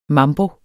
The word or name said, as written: mambo